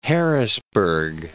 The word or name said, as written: Harrisburg